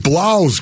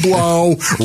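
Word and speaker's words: Blough: And doggone it, to David Blau's credit, rhymes with cow, as they said. They released David Blau, rhymes with cow.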